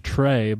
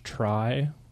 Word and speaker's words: Try: We've been saying Josh Trey, but apparently it's Josh Try. – Well, it is spelled T R Y.